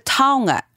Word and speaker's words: taonga: We're also going to check in again, because remember we are getting up to speed here, with Weno Iti referring to taonga as something precious or important to you.